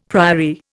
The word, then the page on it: priory: Indian